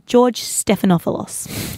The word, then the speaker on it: George Stephanopoulos: Well, since then, he's done a little interview with the ABC News over there, George Stephanopoulos.